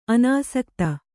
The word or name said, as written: ಅನಾಸಕ್ತೆ